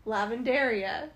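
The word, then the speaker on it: lavandería: Lavanderia? Lavanderia.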